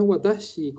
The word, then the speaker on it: watashi: En verdad es Watashi con S-H-I. Sí no es chi, es chi.